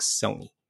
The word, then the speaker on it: Sony: Well, more specifically, we want to talk Sony. Rhymes with bony.